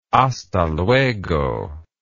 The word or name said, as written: hasta luego